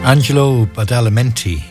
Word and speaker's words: Angelo Badalamenti: Angelo Badalamenti. I pronounced that right.